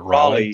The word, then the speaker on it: Raleigh: Is it Raleigh? – Sorry. Raleigh. I said Raleigh before you corrected me. Thank you very much. – I think it's pronounced Roly Poly. – It's Roly. Yeah. It is Roly Poly.